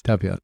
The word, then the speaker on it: Talpiot: Ta- I'm going to mispronounce it, but Tau Talpiot is that Talpiot? Yes, Talpiot.